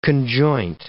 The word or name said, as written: conjoint